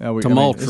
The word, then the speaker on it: tumultuous: Tumultuous. – This means we're saying it wrong. – Say it again. – Tumultuous. – What you mean? – You guys were putting an R in there.